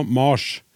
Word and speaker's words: Mars: Stadig oftere jeg planetnavnet uttala med lang A, altså Mars. I mitt talemål fra Sunnhordland har vi alltid uttalt navnet med kort A, og det tror jeg har vært vanlig i resten av landet òg, tidligere.